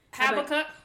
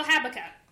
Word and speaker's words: Habakkuk: I feel like I pronounced the cuck wrong, but I did start the haba. – Habakkuk. – Habakkuk. – And I think I said Habuka. Yeah, you definitely did. So Habakkuk.